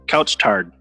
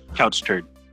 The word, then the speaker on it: Couche-Tard: Couch tard. – Couch tard. How's it spelled? – COUCHE-TARD. Couch turd.